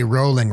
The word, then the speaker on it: Rowling: J.K. Rowling rhymes with bowling.